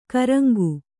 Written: ಕೂರಂಗಿ

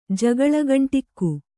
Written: ಜಗಳಗಂಟಿಕ್ಕು

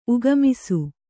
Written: ಉಗಮಿಸು

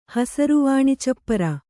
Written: ಹಸರುವಾಣಿಚಪ್ಪರ

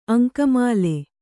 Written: ಅಂಕಮಾಲೆ

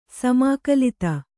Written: ಸಮಾಕಲಿತ

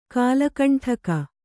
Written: ಕುಲಕಂಟಕ

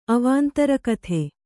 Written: ಅವಾಂತರಕಥೆ

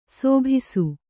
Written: ಶಾಭಾಸ್

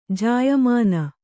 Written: ಜಾಯಮಾನ

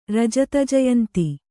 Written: ರಜತಜಯಂತಿ

